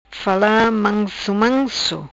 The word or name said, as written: fala manso manso